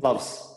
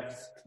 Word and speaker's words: Pfalz: I'm having the Blutengarten German Pinot Noir from, I want to say it's pronounced the Plavz, P F a L Z. Plovs. The Palvs. How would you even? Plovs. – Yeah, yeah, Plovs.